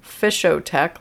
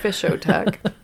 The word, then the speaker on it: Fishotech: Fishotech, like discotech. Fishotech.